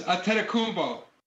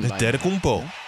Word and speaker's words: Antetokounmpo: It's my honor to announce the 2020 Kia Most Valuable Player is Giannis Antetokounmpo. – Pretty good job on the pronunciation. – Antetokounmpo. – Yeah, he's right there.